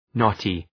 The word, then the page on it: knotty: ‘nɒtı